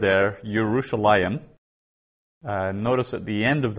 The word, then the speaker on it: Yerushalayim: So we actually pronounce it like that, Yerushalayim, even though the yod isn't there.